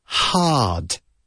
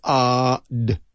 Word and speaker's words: hard: Now, the R, la R, the R in hard is there only to lengthen the vowel sound. Hard, right? You don't need to use your tongue. Don't say hard. It's hard. It's just ard. Ard, and then the aspirate H at the beginning.